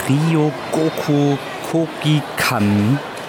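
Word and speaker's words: Ryōgoku Kokugikan: Wir sind gerade in der Ryogoku Kogikan Sumo Ring Halle. Na klar, das war kommt genau richtig japanisch richtig ausgesprochen.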